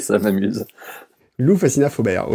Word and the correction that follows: Foubert: oui, Foubert.